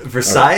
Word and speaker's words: Versailles: Okay, no, Versailles.